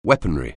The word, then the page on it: weaponry: anglais